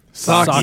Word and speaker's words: Psaki: Psaki. – Socky. Socky, like a pair of socks.